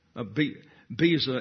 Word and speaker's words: Beza: Beza is the correct pronunciation.